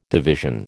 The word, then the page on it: division: American English